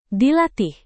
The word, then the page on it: dilatih: Indonesian